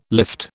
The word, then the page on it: lift: British English